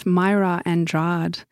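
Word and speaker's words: Mayra Andrade: So up next, I have a gorgeous track off a record by an artist by the name of Myra Andrade. So that's spelled M-A-Y-R-A Andrade, A-N-D-R-A-D-E.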